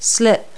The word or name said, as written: slip